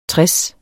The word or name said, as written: tres